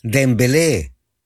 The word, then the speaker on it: Dembélé: ¡Dembélé!